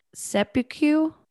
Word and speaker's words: Seppuku: but I'm gonna try to say this word. Seppuku. – That's a good pronunciation, I think.